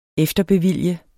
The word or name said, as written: efterbevilge